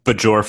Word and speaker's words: Bajor: Bajor? – Bajor for Bajorans.